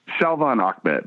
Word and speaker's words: Salvon Ahmed: How does that linebacking core look, and do you have the horses there to stop not just Miles Gasson, but is it Savon Ahmed? How do you pronounce that? – Salvon Ahmed.